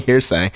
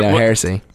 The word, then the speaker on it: Heresy: It's like calling Hearsay. Heresy.